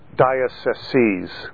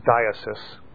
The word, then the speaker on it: dioceses: Each of the countries is divided into one or more dioceses. The singular word is diocese. The plural word is pronounced dioceses. People often say diocese as the plural, but the correct plural is dioceses.